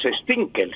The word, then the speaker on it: Stunkel: Y los Stinkel, se escribe Stunkel, pero se pronuncia Stinkel.